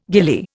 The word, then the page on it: gillie: Indian